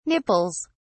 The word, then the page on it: nipples: British English